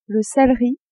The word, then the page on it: celeri: female